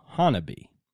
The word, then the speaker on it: Honobia: Now, it is spelt H-O-N-O-B-I-A, but it is pronounced Honabe.